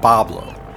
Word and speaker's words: Bois Blanc: Bois Blanc, the venue for this year's ball. Locals pronounced it Bablo, perhaps due to a lack of interest in learning French.